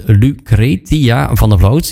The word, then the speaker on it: Lucretia van der Vloot: Lucretia van der Vloot. Ja, zo moet ik het uitspreken.